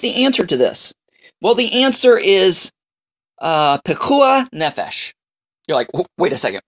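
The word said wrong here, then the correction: pikuach nefesh: Did you just have something caught in your throat? Pekuach nefesh. I even have trouble pronouncing it. Pekuach nefesh.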